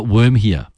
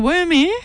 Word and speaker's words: Wurmhere: How about Worm here? – What? How do you spell it? – W-U-R-M-H-E-R-E.